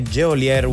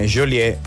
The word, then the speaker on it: Geolier: se uno lo dovesse leggere come si scrive Geolier 1H, invece no, è Geolier Nora.